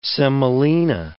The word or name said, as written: semolina